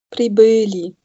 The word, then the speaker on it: прибыли: Прибыли, genau. Also dieses Zeichen da zwischen B und L, dieses Ö, da wird jetzt die Betonung draufgelegt.